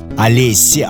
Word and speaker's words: Alessiee: Олесси, а читается гораздо легче, чем пишется, потому что по буквам по-английски A-L-E-S-S-I-E-E.